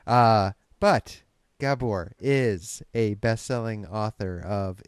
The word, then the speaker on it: Gabor: Did I say it right, Gabor? – Gabor, I'm sorry.